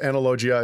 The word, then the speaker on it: Analogia: That's how I would say it, but that's not how the guy who read the audiobook calls it. – Okay, well, good. I'm glad we cleared that up. So from the horse's mouth, Analogia is how you say it.